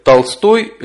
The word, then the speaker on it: Толстой: Например, Толстой Лев Николаевич, ударение делается на последний слог, и «не хочу быть толстой», ударение делается на первый слог.